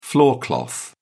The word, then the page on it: floor cloth: British English